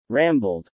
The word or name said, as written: rambled